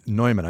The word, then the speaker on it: Neumann: Also founder Adam Neumann's Neumann, I pronounced it like the microphone, Neumann.